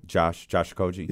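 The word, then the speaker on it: Okogie: How do you say his name? – Josh Okoji.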